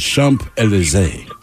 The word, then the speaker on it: Champs-Élysées: By the way, Ted, someone just texted in the pronunciation. It is Champs-Élysées.